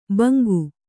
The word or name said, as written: ಬಂಗು